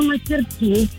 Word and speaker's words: мастерски: Скажите, пожалуйста, как вы говорите, мастерски или мастерски? Все-таки, куда поставить ударение правильно? – Ну, вообще, я говорю мастерски на последнее «и».